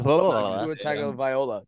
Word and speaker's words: Tagovailoa: Well, it's better going Tagovailoa. Tua Tagovailoa. There you go. – Tagovailoa.